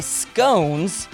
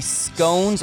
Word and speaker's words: scones: I need some help on this, though, because he said it's pronounced as in blowin', like B-L-O-W-N. – So I think he's saying you can pronounce it both ways. – Blowin' or gone. You can do either. So it can be scones or scones.